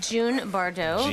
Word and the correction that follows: Jean Bardot: Jean Bardot. Jean. – Like Jean as in Jean Simmons, spelt with a J? – Yeah, exactly. Like Jean Harlow.